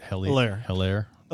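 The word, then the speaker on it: Helaire: Hilaire. – Thank you. And then at 12. – Like Eclair without the Claire.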